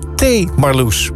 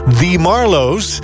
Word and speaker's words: The Marloes: Deze bandnaam namelijk T. Marloes. Dus als in wil je nog een kopje thee, Marloes? Maar zo spreek je het natuurlijk niet uit, Jaap. Nee, het is The Marloes.